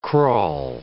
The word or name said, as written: crawl